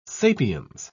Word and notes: sapiens: British English

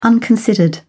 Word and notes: unconsidered: British English

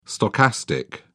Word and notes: stochastic: British English

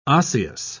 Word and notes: osseous: British English

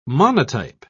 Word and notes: monotype: British English

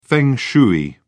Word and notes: feng shui: British English

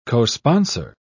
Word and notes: cosponsor: British English

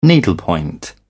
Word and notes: needlepoint: British English

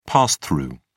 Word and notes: pass through: British English

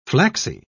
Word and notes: flaxy: British English

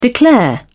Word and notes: declare: British English

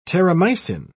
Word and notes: terramycin: British English

